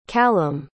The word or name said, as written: callam